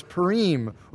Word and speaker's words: Purim: The Assyrian word for lot is pur, P-U-R, not P-U-R-E, pur or pronounced pur. The plural form of that word, in Hebrew, you add an im on the end, so it's Purim or Purim, we say.